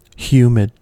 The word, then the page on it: humid: American English